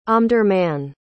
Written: Omdurman

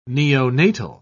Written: neonatal

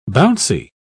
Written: bouncy

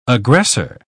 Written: aggressor

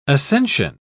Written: ascension

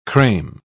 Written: creme

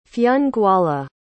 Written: Fionnghuala

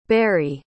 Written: barry

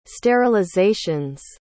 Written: sterilizations

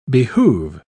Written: behoove

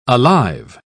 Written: alive

